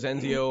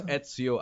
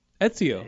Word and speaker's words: Ezio: Everybody always says Enzio or Enzo. It's Ezio Auditore da Firenze. – I'm not saying that. – It's Ezio. – Wow. – Do you ever have an Etch-a-Sketch growing up? – Ezio. Could I get it? – Yeah, Ezio. – Just think Etch-a-Sketch. – I'll get it. – It's spelled E-Z-I-O, so people are like, Ezio.